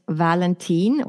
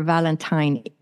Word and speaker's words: Valentin: Yeah, so my name is Valentine or Valentine. It can actually be pronounced in two different ways. And that's because I was born and raised in Sweden, which is in Northern Europe, all the way up by the North Pole, Scandinavia.